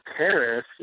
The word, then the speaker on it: charis: It's charis, yeah.